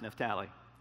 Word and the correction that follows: Naphtali: It's Naphtali. And then I joked with him. I said, you got to remember, I'm from small town Alabama. You can take the boy out of small town Alabama. You can't take the small town out of the boy. So there's ways that we pronounce things with the wrong syllables, so you'll have to bear with me. But Naphtali is how that's said.